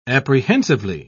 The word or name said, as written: apprehensively